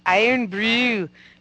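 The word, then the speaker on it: Irn-Bru: Iron Brew.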